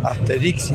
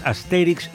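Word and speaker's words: Astérix: Yo creo que la pronunciación correcta es Asterix y Obelix, pero yo le decía Asterix y Obelix, o sea, tres acentuaciones diferentes.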